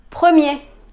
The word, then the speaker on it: premier: Y también está la palabra premier. Premier. Mie.